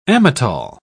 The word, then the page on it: amatol: British English